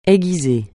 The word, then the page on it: aiguiser: français